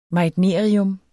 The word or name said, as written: meitnerium